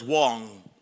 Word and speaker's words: Wang: My name is Solomon Wang. Put that picture up again, please. My name is Solomon Wang, pronounced as Wong. It's W-A-N-G, pronounced as W-O-N-G. And if you say Wang, that really would be Wong.